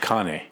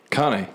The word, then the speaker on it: Cane: I'm going to correct you, though, on how you pronounce it. – I know. I was just going to say, because I say King Corso, but you've been saying it. – Cane.